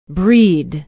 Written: breed

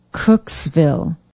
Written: Cooksville

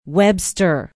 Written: webster

